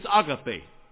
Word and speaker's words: agape: So in Greek, it's agape. You people say agape here, you don't say pronounce it right, but that's okay.